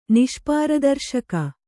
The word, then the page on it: ನಿಷ್ಪಾರದರ್ಶಕ: kannada